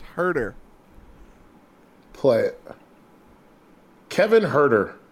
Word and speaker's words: Huerter: Huerta, Herder. That's not how you. Yeah, no, it's Herder. Someone came at me on Twitter for saying Huerta.